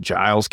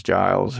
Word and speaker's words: Giles: This is just, it's all rural, small places in, I think it's pronounced Giles County. It's with a G, though, so Giles, who knows.